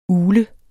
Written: ugle

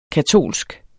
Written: katolsk

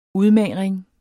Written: udmagring

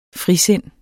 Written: frisind

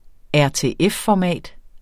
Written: rtf-format

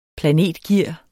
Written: planetgear

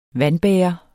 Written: vandbærer

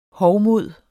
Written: hovmod

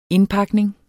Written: INDPAKNINGER

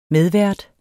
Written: medvært

